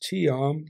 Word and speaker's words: Chiam: And it's pronounced Chiam like that, right? Go Chiam, so Lukh Chiam, right?